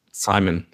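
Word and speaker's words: CIMON: And one of your early projects, I learned, was Simon, or how do you pronounce it? Simon? – Simon, yeah.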